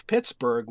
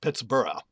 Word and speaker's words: Pittsburgh: And his bet was that probably both were pronounced Thornburgh, as they would be in Scotland. And therefore, Pittsburgh possibly was originally pronounced Pittsburgh.